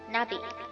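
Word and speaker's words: Nabi (나비): 原因很简单，欧阳娜娜呢有一个爱称叫做欧阳娜迪，也就是娜迪，而蝴蝶在韩语的读音呢是 Nabi。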